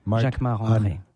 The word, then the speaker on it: Jacquemart-André: Jacquemart-André. Jacquemart Arnaud, you say it like André. A Frenchman. Jacquemart of course, because I'm French. So Jacquemart André.